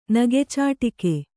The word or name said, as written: ನಗೆಚಾಟಿಕೆ